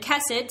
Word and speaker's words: Khesed: And Kessid, since it's a nice and simple English word, is spelled K-H-E-S-E-D.